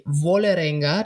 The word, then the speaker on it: Vålerenga: Da haben sie eben nicht die beste Leistung gezeigt, aber jetzt in der Champions League noch ungeschlagen, weil sie auch ja gegen Vålerenga, damit wir wissen, dass es eben nicht so spanisch ausgesprochen wird, gegen das norwegische Team auch noch einen Last-Minute-Sieg hingelegt.